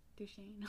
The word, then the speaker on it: Duchene: Duchene.